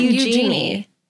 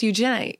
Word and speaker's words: Eugenie: It's not Eugenie. – It's Eugenie. Eugenie. – Yeah. – You got to get a little Cockney accent going there. – You have to say it like, use your knee.